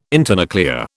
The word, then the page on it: internuclear: British English